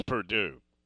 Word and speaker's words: Purdue: Jordan up in West Lafayette would not appreciate you pronouncing it Purdue. It's Purdue.